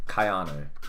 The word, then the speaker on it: Keanu: Yeah, Keanu. – Anyway. – It's pronounced it's like K-I and then Anu. – Keanu. – Key, like the Dragon Ball Key.